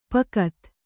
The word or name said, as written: ಪೋಕ್ತ